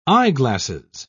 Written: eyeglasses

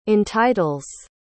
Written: entitles

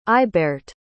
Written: ailbeart

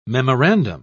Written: memorandum

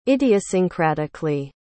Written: idiosyncratically

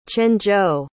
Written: quanzhou

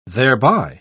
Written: thereby

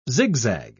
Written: zigzag

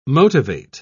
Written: motivate

